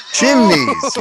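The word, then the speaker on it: Ximines: O'Shane Chimneys. – Chimneys, yes.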